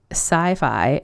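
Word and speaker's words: Psi Fi: So the Louise fic I found to share with you folks is called Pez is Good by Sci-Fi, and that is spelled P-S-I space F-I.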